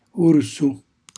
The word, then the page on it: ursu: Calabrese